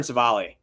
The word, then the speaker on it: Civale: It's Aaron Civale. That's how I say his name, right? Did I do it right? – Yes.